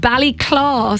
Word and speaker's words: Ballyclough: Okay, I'm glad to report that Gerard Cork has been in touch and said Ballyclaw, spelled C-L-A-W, is the local pronunciation of B-A-L-L-Y-C-L-O-U-G-H.